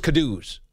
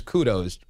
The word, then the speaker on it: kudos: And I was like, kadoos? Like kazoos? What do you mean? Like little plastic flutes? They deserve kadoos? Oh, no, that's not how you produce kudos. Pronounce kudos, is it?